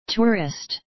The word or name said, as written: tourist